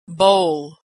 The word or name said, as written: bowl